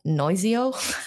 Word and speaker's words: Noizio: It is called uh, called Noizio.